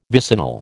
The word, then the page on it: vicinal: American English